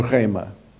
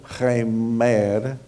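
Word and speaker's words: rema (ῥῆμα): A palavra é rema. Não é remer, como algumas pessoas a pronunciam, pronunciam a minha, meu nome. Não é remer, é rema.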